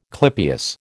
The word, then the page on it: clypeus: American English